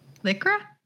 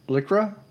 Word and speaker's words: Lycra: Lycra? – Lycra? Did I say that wrong? – No, it's Lycra. – Lycra.